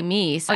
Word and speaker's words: Meiss: My name is, it should be Meiss if it's pronounced the German way. – Oh, I'm sorry. Is that how you? – No, we say Meiss.